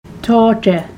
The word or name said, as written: tooTe